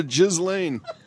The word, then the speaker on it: Ghislaine: G-H-I-S. – No relation. – No, that's her first name. G-H-I-S-L-A-I-N-E. I mean, that's how I would pronounce that, right? Ghislaine. – Yeah. – Right? – Yes.